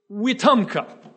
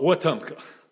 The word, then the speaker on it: Wetumpka: What can one person do in Wetumpka? I've been taught to pronounce that correctly. I used to call it Wetumpka. That's wrong.